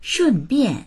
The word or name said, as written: shun4bian4